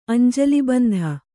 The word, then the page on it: ಅಂಜಲಿಬಂಧ: Kannada